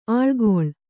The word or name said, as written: ಆಳ್ಗೊಳ್